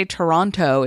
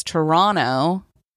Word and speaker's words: Toronto: Isn't, I feel like, so it's like when you try to say Toronto, it's Toronto. or like that's how they say it. And Melbourne is Melbourne, as far as I understand.